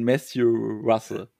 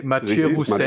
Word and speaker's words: Mathieu Roussel: Und Matthew Russell. – Nee, Mathieu Roussel. – Mathieu Roussel, würde man es französisch aussprechen?